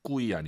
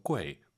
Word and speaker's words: 贵: 꾸이 아니고 UI 사이에 에 넣어서 꾸이, 꾸이 시 꾸이 이렇게 발음하시고요.